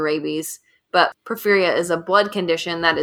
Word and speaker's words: porphyria: And then the last medical disorder that I'm going to discuss that may have fueled vampire speculation is porphyria. Right, James? That's how you say it? – Yep.